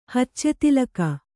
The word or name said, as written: ಹಚ್ಚೆತಿಲಕ